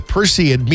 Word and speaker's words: Perseid: And the Perseid meteor shower reaches its peak this week. I say that right? Yep. There we go.